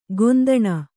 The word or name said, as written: ಕುಂದಣಿ